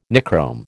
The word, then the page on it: Nichrome: American English